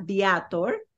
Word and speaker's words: Viator: Todo lo que fue tour, usamos un website que se llama Viator, como lo pronunciaría en español, V-I-A-T-O-R.